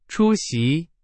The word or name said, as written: 出席